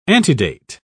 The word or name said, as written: antedate